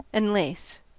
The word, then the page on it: enlace: American English